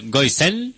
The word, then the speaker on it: Goisen: Pues nos despedimos con el tercer tema, que de hecho es el single, el primero de este disco, que, bueno, de Goisen. – Sí, es como, lo he dicho bien.